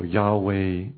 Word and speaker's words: Yahweh: So Yahweh is the pronunciation using those same consonants, but the right vowels as best as that can be determined.